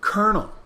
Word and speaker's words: colonel: Colonel. Now, that's because of the spelling. It's spelled colonel. C-O-L-O-N-E-L.